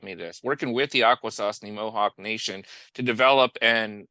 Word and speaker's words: Akwesasne: Looks like there's some very uh, fun chemicals that we'll be talking about here um, with the Akwesasne Mohawk women. – Well done. You got the pronunciation just right.